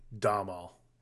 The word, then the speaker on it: Domhnall: I believe you're supposed to pronounce it Domhnall.